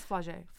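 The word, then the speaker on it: Flau'jae: I think it's Flaujay.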